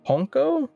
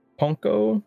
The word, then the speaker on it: Pwnco: This was known as the... Ponco? It's just P-W-N-C-O.